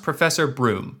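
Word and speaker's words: Bruttenholm: Depends on who you ask in this movie, because the character's name is it's pronounced Professor Broom, but it's spelled Brutenholm. And somewhere in the many, many, I assume, reshoots in this movie, they got those two mixed up and they start pronouncing it Brutenholm.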